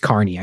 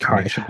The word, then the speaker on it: Kearney: Oh, is it Kearney or Kearney? It's Kearney. I know it's pronounced Kearney. She'd slap me for that. She would slap me. It's Kearney.